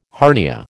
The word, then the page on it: hernia: American English